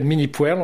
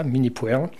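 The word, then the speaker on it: Minnie Pwerle: Moi, je l'appelle Mini Puerle. On ne devrait pas prononcer comme ça, mais on va dire que les Français prononcent comme ça. Donc moi aussi, je le prononce comme ça, Mini Puerle, qui est décédée maintenant, qui a commencé à peindre à presque 90 ans.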